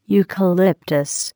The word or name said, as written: eucalyptus